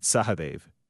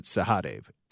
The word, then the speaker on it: Sahadev: I don't say it the Indian way. It's pronounced, my parents would pronounce it Sahadev, and I pronounce it Sahadev.